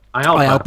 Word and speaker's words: IALPA: The impact trade union with uh, w- which IALPA is, is it IALPA is it what how am I supposed to pronounce That's that? That's it. Yep. IALPA, IALPA correct.